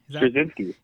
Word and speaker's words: Straczynski: Straczynski? – Straczynski.